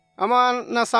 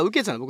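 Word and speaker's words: 雨穴: アマーナさん？アメアナ？アマーナ？ちょっと呼び方わかんない。じゃあ、ウケツだ。読み方ウケツなの？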